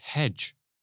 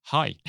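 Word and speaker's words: hej: So I had a friend who went around for the first day in Denmark saying hedge to people, not realizing that it's pronounced hi.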